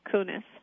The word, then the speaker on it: Koones: So I've mispronounced your name all the way through the interview, and it's spelled K-O-O-N-E-S. Is that right. – Correct? – That's right. And it's Sherry Kunis.